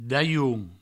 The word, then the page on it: dalhon: Provençal; daioun